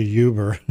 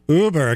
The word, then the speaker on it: Uber: Did I say it right? I always say Uber. It's Uber. It came public this week.